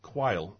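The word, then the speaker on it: Quayle: 125 Quail.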